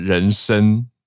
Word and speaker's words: 妊娠: 嗯，就是写成妊妊，我们口语讲妊娠，可是读音是人生。